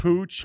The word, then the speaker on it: Puig: I pronounce it Pooch? Ricky Puig, but I've heard vastly different pronunciations. – Pooch.